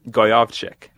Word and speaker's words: Gojowczyk: How do you pronounce that again? – Goyovchik. – Gojowczyk.